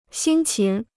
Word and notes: 辛勤: xīn qín

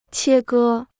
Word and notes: 切割: qiē gē

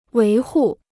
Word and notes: 维护: wéi hù

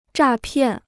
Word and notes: 诈骗: zhà piàn

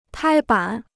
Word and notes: 拍板: pāi bǎn

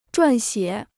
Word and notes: 撰写: zhuàn xiě

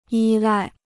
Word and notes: 依赖: yī lài

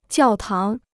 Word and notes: 教堂: jiào táng

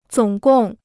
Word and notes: 总共: zǒng gòng

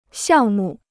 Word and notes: 项目: xiàng mù

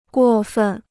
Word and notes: 过分: guò fèn

